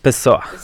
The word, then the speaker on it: Pessoa: Pesado, como brasileño. – Pessoa. – Así que, por ahí va la pronunciación.